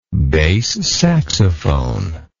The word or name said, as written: bass saxophone